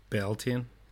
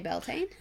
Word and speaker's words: Beltane: Beltane. – You would just say Beltane? – Yeah.